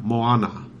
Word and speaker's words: Moana: I'm pretty sure it's Mona. – All right. – Pretty sure. – I think I'm right. – I don't know. – It's Hawaiian. – Yeah? – In Hawaiian, you pronounce every vowel. Moana.